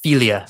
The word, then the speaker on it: Figli: It's technically pronounced Filia. Filia. Del Coro. Filia yeah. de Coro. – This it's, was like it's spelled Figli del Coro, but it's pronounced yeah.